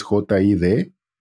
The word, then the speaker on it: JID: Sí, no, y por último, porque ya sé que me estoy pasando de lanza, el álbum nuevo de JID, se pronuncia, es JID, se llama The Forever Story.